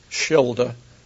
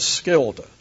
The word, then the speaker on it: Schilder: I pronounced it Schilder, S-C-H, in Dutch. The Dutch, contrary to the Germans, like to pronounce the S-C-H, I think, out of enmity toward the Germans, as Skilder. So I'm calling it Skilder.